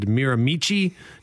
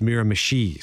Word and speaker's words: Miramichi: That the federal government has been dealing with, where I said Miramichi, New Brunswick, and I've been told it's Miramichi.